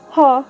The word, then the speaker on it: ha: Ha, colloquially pronounced as ah, is a more standard plural ending, used here after sheikh or sheikh.